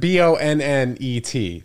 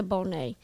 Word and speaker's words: Bonet: And you're like, I know that Bonnet, something like that, right? Or like it's spelled. I've seen the name.